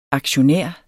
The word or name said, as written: aktionær